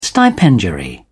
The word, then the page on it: stipendiary: British English